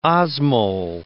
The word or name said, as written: osmol